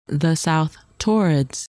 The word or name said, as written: South Taurids